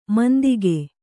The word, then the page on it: ಮಂಥಿಕ: Kannada